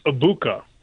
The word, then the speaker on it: Egbuka: By the way, Mike, I believe the G is silent. I believe it's Egbuka.